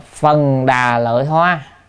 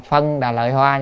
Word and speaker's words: phần đà lợi hoa: Phần đà lợi hoa, có khi là đọc là phân đà lợi hoa, nhưng mà chúng ta nên đọc phần đà lợi hoa.